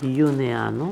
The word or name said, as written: Uneano